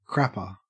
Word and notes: crapper: Australian English

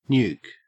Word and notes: nuke: Australian English

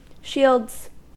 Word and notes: shields: American English

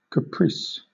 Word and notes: caprice: British English

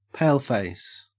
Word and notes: paleface: Australian English

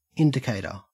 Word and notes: indicator: Australian English

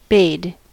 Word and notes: bade: American English

